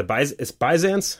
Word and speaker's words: Byzance: That's a minor, I think I read it was a the Byzance? Am I saying that wrong? – No, that's right.